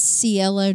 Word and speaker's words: Cielo: It's Cielo Drive.